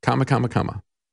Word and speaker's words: Kamala: That's the way you pronounce it. Kamala, or chameleon.